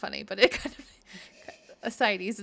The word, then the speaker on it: ascites: It's like A-S-C-I-T-S. – Hold on. Everyone, hold on. Ascites. Ascites, which is basically like collection of water or something like that.